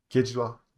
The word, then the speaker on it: Kejriwal: Kejriwal. Did I say his name right? – Kejriwal.